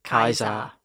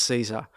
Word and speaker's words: Caesar: And how different Julius Caesar's name would have been. So it would have been Julius. – Julius. Kaiser. – Kaiser, yeah. – So completely not Julius Caesar.